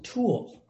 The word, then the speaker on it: Tuel: So, and my last name was Tuel, which is kind of an odd name. I'd have to spell it every time. T-U-E-L. And they would say T-E what? No, T-U-E-L.